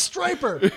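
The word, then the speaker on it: Stryper: It's pronounced Striper.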